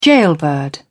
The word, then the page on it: gaolbird: British English